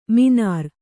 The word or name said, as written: ಮೀನಾರ್